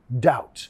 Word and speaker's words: doubt: No doubt. Sin duda. Va a pasar. No doubt. Nota acá que no tienes que pronunciar la B. No es doubt, but, más doubt. D, au, terminando con la T.